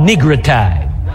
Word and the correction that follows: nigritude: And I apologize, I mispronounced the word. It's nigritude. Congenital disease.